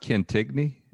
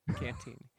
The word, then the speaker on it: Cantigny: There's isn't that pronounced Cantigny? – No, Cantini.